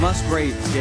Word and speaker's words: Musgraves: He's Musgrave. – Hey, you got to call her Musgrave. – I got the name right. That was a great line. Musgraves? – Musgraves, yes.